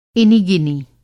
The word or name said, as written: इनी-गिनी